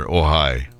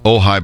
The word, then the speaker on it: Ojai: You see, the Wildlife Rehabilitation Center, Ojai Raptor Center, has not collaborated with anybody to make merchandise.